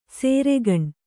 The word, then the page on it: ಸೇರೆಗಣ್: sēregaṇ